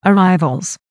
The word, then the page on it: arrivals: American English